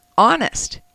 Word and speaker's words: honest: Honest. The H is silent.